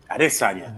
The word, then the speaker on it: Adesanya: Adesanya. But, that's how you say it last time. Adesanya. That's right. – I like that shit. I like that. You got to say it the right way.